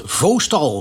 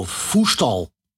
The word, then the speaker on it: Voestalpine: Oh, ik dacht Voostal, Voestal.